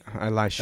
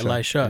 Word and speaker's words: Elisha: I'm I'm sorry, I don't know if it's Elisha, Eliseo. Is that how you say it? – Elisha. Elisha. Eliseo so Elijah Elisha was only with Elisha for a season.